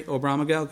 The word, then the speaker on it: Oberammergau: Did I pronounce that right, Sherry? Oberammergau? Good.